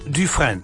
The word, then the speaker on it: Dufresne: Und der schreibt sich folgendermaßen. D-U-F-R-E-S-N-E. Etwa ausgesprochen Dufresne. Dufresne.